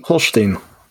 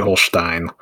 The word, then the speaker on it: Holstein: Holstein. – Okay. There we go. – Holstein. All right. It's actually Holstein.